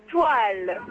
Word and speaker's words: toile: Se dice toile. – Toile, como estrella, pero sin E. – T-O-I-L-E.